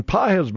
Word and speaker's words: Pages: Páez, by the way, I could see where that could be mispronounced because it's spelled P-A-G-E-S, like right. Pages.